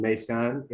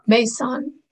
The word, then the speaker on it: Maysan: I was born and raised in Maysan, Iraq. – Maysan.